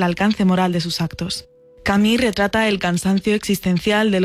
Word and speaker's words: Camus: Narrado por nuestros compañeros de Un libro, una hora, inaugura la primera novela del escritor argelino-francés Albert Camus, o Camus, como se pronuncia en su idioma natal.